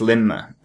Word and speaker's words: Lindmer: So it is spelled Lindmer, but it's Linmer. Is the D is silent.